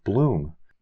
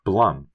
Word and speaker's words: Blum: Finally, when Alex was talking about the new Blumhouse movie on Amazon Prime, he pronounced it as Bloom, when it's actually Blum, as in Blumpkin. Not Bloom, as in if you eat the Bloomin' Onion appetizer when you have dinner at the Outback Steakhouse, there's no way you're getting a Blumpkin later.